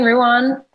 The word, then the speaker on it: Rouan: It's Adrian Ruan. That's my name. It's everything is Adrian Ruan. I've made it as simple as possible. And Ruan is spelled R-O-U-A-N.